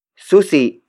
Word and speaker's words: susi: Susi is spelled as S-U-S-I.